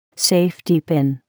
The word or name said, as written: safety pin